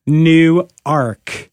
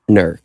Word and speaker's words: Newark: No, Newark, New Jersey is pronounced Newark. – Nurk. – Yeah, there's no vowels or anything. It's basically N-W-R-K.